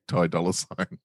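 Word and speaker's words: Ty Dolla $ign: Like, it's when you look at it, it's like point. THY. THY dollar sign. But when you say his name, you've got to say Ty dollar sign.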